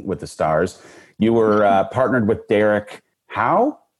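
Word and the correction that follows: Hough: Derek Huff.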